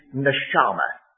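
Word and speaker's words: Neshama (נשמה): This particular word is capital N apostrophe S-H-A-M-A-H, and pronounce it Neshama, if you want to look it up in your concordance.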